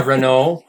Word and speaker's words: Renault: Yeah, Renault. I'm probably not saying it quite French enough, but they had a car called the Kangoo.